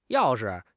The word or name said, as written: yao4 shi5